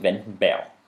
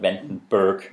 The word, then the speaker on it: Vandenberg: Og der står, jeg læste som Vandenberg, men det udtalte åbenbart Vandenberg. – Ja. Så dansk i starten, og så engelsk til sidst.